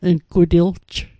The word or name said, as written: ngutilj